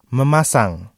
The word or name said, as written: memasang